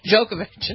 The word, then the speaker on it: Djokovic: Is that Djokovic. – How you pronounce it? – Mm-hmm. – The D is silent?